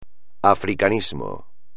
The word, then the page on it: africanismo: espagnol